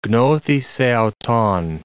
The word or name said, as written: gnothi seauton